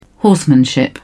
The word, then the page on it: horsemanship: British English